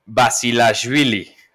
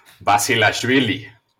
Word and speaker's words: Basilashvili: Al que le ganó Alcaraz es Basilashvili. – Correcto. – Basilashvili.